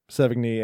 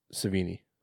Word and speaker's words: Sevigny: Do you know that's how it's pronounced, her last name? – I've heard it pronounced both ways. – I've heard it both ways. – Yes. – Like psych. – Yeah, like that. – You say both ways. You mean Sevigny and Sevigny.